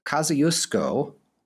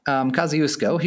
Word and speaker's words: Kosciuszko: The final partition of Poland occurred in the aftermath of the Kosciuszko uprising. Hope I pronounced that right. All right, so that is probably did. Yeah. I'm going to say that you did it perfectly.